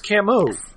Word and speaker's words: Camus: Oh, it's pronounced Camus.